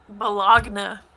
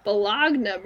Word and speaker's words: bologna: I would spell it B-A-L-O-N-E-Y. That's what it's like. But no, yeah. We got G's in there, man. – Bologna. – Bologna, bro.